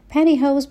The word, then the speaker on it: pantyhose: Pantyhose به معنای جوراب شلواری هستش. اون تی میتونه خیلی کم رنگ تلفظ بشه توی لهجه آمریکایی، به جای اینکه بگیم pantyhose.